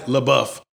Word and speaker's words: LaBeouf: LaBeouf.